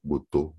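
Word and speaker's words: Boutaud: E dessa vez eu tive o maior prazer de conversar com a Virginie Butá, também com Botô, melhor dizendo, falei errado o nome dela, sobrenome agora, mas ah, no programa eu falei certinho Virginie, a maioria das pessoas conhece apenas pelo prenome, é a vocalista do Metrô.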